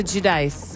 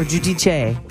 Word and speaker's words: Giudice: Teresa Giudice or Giudice. – Oh, I'm going with the Italian pronunciation.